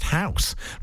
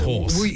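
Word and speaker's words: Hause: Well, Jimmy says, I know it's going to ruin your feature, but I think it's pronounced House rather than Horse.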